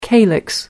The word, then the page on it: calyx: American English